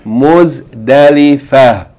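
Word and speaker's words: Muzdalifa (مزدلفة): We usually say Muzdalifa because if you pronounce the word by itself, you're going to pronounce it in the pause form, so you're not going to pronounce the ta marbuta as a T with this vowel. You're going to pronounce it as a H, so it became Muzdalifa.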